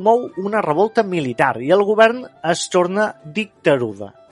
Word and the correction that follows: dictadura: com Dictadura.